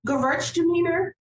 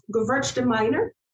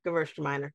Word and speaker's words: Gewürztraminer: It's not pronounced Gewürztraminer. It's pronounced Gewürztraminer. – Yes. Okay. Gewürztraminer. Yes. I can see that. It's German.